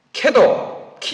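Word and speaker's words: KEDO: 캐도, 키도라고 발음합니다만 키도 또는 캐도를 받아들일 때 한국형 원자로를 한국기술진에 의해서 건설하도록 용납했을 때 이미 북한은 한국의 기술에 에너지를 의존한다는 결정을 한 것입니다.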